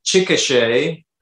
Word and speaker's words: Chickasha: So you grew up in Chickasha, Oklahoma. Is that right? – Yeah. Chickasha.